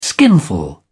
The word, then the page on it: skinful: British English